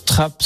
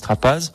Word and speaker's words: Trappe: Oui, Trappe, c'est le lieu, mais la prononciation se dit comme ça en local, mais c'est vrai que les gens disent Trapaz.